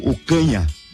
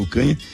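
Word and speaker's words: Ocaña: Se vocês quiserem assistir o clipe, é Marcelo Ocanha. É, se escreve Marcelo Ocana, com aquele tiozinho em cima ali, né? Marcelo Ocanha.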